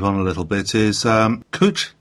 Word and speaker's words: Cwtch: And I do believe that Cwch is the correct pronunciation, though it's spelt C-W-T-C-H.